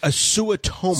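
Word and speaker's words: Suotamo: It is Suotomo. – Suotomo.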